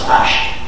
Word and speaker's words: Ishq: But if you want to tell someone I love you with no room for mistake, you will use the word Ishq. That you pronounce Ash.